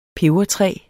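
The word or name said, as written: PEBERTRÆ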